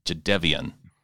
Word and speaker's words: Jadeveon: not an on, it's an un, and it's not a Dave, it's a Dev. – Dev? Jadeveon. Jadeveon. Okay. Jadeveon. – Not an emphasis on the E, though. The emphasis is on the Dev. Jadeveon.